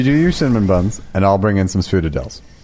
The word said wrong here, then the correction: Sfogliatella: let me just try it. Sfogliatella. – That's it. – Okay. – All right. Sfogliatella.